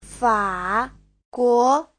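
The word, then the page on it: faguo: fa3guo2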